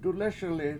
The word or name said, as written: dulES1li